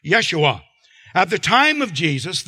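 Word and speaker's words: Yeshua (ישוע): Jesus is pronounced Yeshua in Hebrew, and it's the same name as Moses' assistant Joshua, a deliverer.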